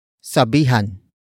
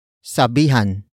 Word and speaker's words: sabihan: The first word is sabihan. Sabihan. Sabihan. Sabihan is spelled as S-A-B-I-H-A-N.